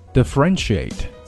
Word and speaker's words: differentiate: Next one, differentiate. Differentiate 重音呢在第三个音节 run differentiate 差异化使之不同。